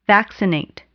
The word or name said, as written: vaccinate